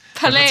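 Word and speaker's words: Palaye Royale: Palais, oder? Wir hatten eben noch geguckt, Palais. Weil wir so unsicher waren. – Ich habe gesagt Palais. Ich habe es Französisch ausgesprochen. – Ach so, Palais. Du hast recht, ja, genau.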